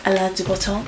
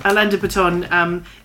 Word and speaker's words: Alain de Botton: Alain de Botton. – Alain de Botton.